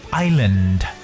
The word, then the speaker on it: island: 这样的单词还真的是比较多的，比如说现在 那马上能想到的一个单词呢，就是小岛或岛屿这个词，它的拼写是 I S A N D，可是呢，我们把它读成 island。 island 就是里边的这个 s 是不发音。